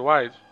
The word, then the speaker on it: Wilde: uma coisa aí, um sotaque britânico pra falar Oscar Wilde? – Não. – Ah, droga. – Eu pronuncio como se fosse selvagem, porque eu já acho muito interessante.